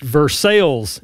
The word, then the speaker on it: Versailles: But I will say it's pronounced Versailles in Kentucky, even though the place in France might be Versailles.